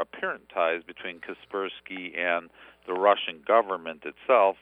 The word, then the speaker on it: Kaspersky: Kaspersky, yeah.